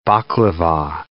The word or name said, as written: baklava